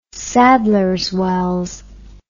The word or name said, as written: sadlers wells